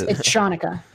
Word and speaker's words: Hanukkah: Isn't it pronounced? You know better than I do. – Yeah. Yes. – It is. It's Hanukkah. Yeah.